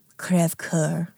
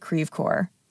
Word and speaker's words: Crèvecœur: It's a French name, Crèvecœur. But we pronounce it Crèvecœur. That's Midwestern French.